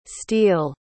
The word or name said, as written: steele